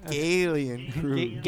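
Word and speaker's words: Gallien-Krueger: Or Galen Kruger. I don't want to mispronounce. – Yeah, get Midwest with it, dude. Galen Kruger. – Galen Kruger. – Yes, Galen.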